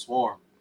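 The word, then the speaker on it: CWORM: My name is Swarm, but I spell it C Worm, mm-hmm. even though it's pronounced Swarm.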